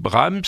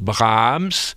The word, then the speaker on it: Brahms: Nous on dit Brahms, et les Allemands le prononcent Brahms, ils accentuent le A, parce que c'est une voyelle D'accord. – qui est suivie d'un H.